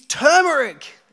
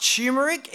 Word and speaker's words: turmeric: It's got an R in it. Or does it? People have started selling turmeric. It's turmeric. It has an R in it, but it is so commonly pronounced turmeric that people have started spelling it as such.